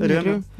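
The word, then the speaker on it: Römer: То есть вы видите кресло, ну, например, вот э, Рикара или Ремер. Ремер? Правильно говорю? – Да, Рю, рюмер. Бритекс Рю... рюмер, если вот совсем правильно.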